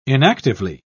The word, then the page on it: inactively: British English